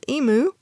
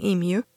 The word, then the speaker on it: emu: The emu, often pronounced emu by Americans, but that sounds funny to me, so I'm saying emu.